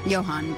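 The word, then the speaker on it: Jóhann: Johan, probably then. – Yeah. – Not Johan. – Soft J. – No one does that. – Like yogging.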